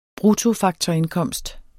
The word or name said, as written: BRUTTOFAKTORINDKOMST